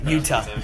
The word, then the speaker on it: Jutta: I feel like that's also um, one of the things he also struggles with is his relationship with his sister Jutta I, am sorry, I do not know how to pronounce Utah. that name right. – Utah. Utah, thank you.